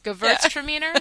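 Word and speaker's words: Gewürztraminer: W is a V, I guess. Gewurztraminer, is that it?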